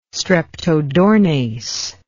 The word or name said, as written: streptodornase